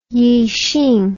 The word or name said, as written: Yixing